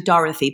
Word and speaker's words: Dorothy: Which is actually Dorothy, but no one pronounces it right.